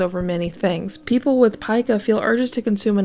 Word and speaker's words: pica: Pica, and it is pronounced pica, but you may hear people say pica, is a disorder where someone has a compulsion or craving to eat items of no nutritional value.